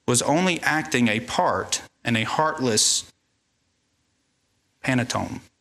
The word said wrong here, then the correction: pantomime: Pantomime. Anybody pronounce that one? Pantomime, thank you.